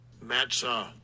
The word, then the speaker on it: matzah: So this is how you pronounce this word in Hebrew. – Matzah. Matzah.